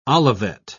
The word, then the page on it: olivet: British English